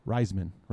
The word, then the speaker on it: Reisman: Jeremy Reisman, right? – You got it.